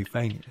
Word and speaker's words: Fania: I think it is Fania, because you know what? Amy used to work with someone called Faye. – Okay. – Her full name was actually Fania.